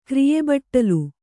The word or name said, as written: ಕ್ರಿಯೆಬಟ್ಟಲು